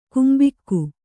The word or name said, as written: ಕಂಬಿಗ